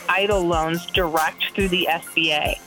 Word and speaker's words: EIDL: But I want to go back to the first loan that we were talking about, the Economic Injury Disaster Loan, or EDL. Wait, we pronounced it EIDL. My bad. I'm giving it totally new initials.